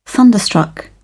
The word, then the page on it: thunderstruck: British English